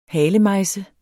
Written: halemejse

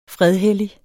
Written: fredhellig

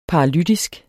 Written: paralytisk

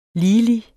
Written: ligelig

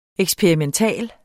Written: EKSPERIMENTAL

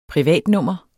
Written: privatnummer

